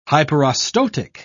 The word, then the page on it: hyperostotic: British English